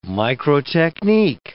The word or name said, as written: microtechnique